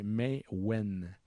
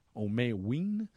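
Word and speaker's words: Maïwenn: La prononciation, c'est May Wen. May Wen. – May Wen. – C'est M-A-I-W-E-E-N.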